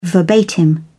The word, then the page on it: verbatim: British English